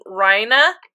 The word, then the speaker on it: Reiner: You'll meet a character named Reina, but it's spelled Reiner. But they always say Reina.